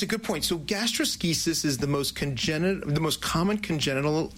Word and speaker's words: gastroschisis: So this condition, gastroschisis. Did I say that right? – Said it perfectly.